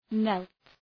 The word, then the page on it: knelt: nelt